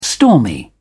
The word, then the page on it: stormy: British English